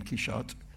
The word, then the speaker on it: Quichotte: And the French has always been Quichotte, and the German is Quichotte, and the Italian is Quichotte.